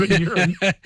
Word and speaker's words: Neuren: we both said it wrong here. – Neuren, they've got the best deal here because they don't actually manufacture or sell the drug themselves.